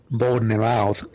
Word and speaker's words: Bournemouth: Por cierto, hablando de Bournemouth, mira lo que te dice un oyente. – Chicos, una cosa, no se pronuncia Bournemouth, se pronuncia Bournemouth.